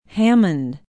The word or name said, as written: hammond